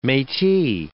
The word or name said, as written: metis